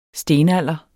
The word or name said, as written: stenalder